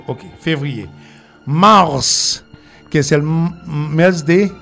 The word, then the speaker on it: mars: Se pronuncia Mars como la Marte. – Como Marte.